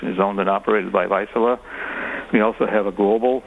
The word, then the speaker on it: Vaisala: What kind of work do you do at Vaisala, which is spelled V A I S A L A? – Yes, it's Vaisala.